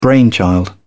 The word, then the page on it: brainchild: British English